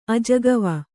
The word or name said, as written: ಅಜಕವ